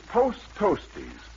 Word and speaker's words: Post Toasties: Posties, or Host Posties, or that is Roast Coasties, I mean Toast Posties, presents, or present... – Hey, get the name right, my confused friend. It's Post Toasties.